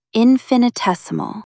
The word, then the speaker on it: infinitesimal: Put the stress right on the fourth syllable. Infinitesimal. Try saying it. Infinitesimal.